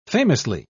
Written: famously

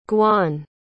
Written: gwaun

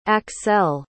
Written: axelle

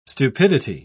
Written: stupidity

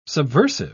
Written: subversive

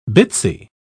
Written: bitsy

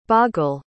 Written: baghel

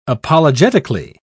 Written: apologetically

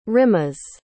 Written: rimaz

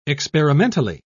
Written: experimentally

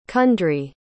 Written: cundrie

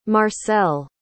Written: marcelle

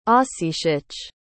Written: ossetic

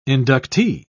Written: inductee

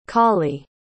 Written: colly

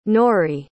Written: Nori

